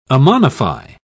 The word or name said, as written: ammonify